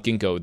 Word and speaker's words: Ginkgo: Like the tree. Think ginkgo tree or ginkgo leaves.